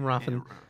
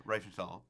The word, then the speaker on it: Riefenstahl: You say her name, Riefen? – Riefenstahl.